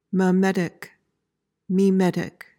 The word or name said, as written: memetic